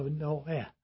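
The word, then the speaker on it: no eh (Νῶε): we would say no, make a long O out of it with the E on the end. But in Greek, you'd actually pronounce that E as eh. So no eh.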